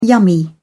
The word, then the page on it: yummy: British English